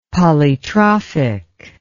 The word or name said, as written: polytrophic